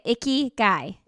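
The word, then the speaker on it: ikigai: That's how you say it, icky guy. – Yep. It's I-K-I-G-A-I.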